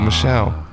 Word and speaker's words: Michel: Is it Michael or Michelle? – It's Michelle. I know it's Michelle.